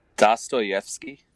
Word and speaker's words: Dostoevsky: Uh, it's Dotsvog Ki I, can't ever get it right the first time, it's man. Dostoevsky.